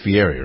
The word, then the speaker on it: Fieri: Is that how you pronounce his name? It's Fieri, right? – It is, yeah.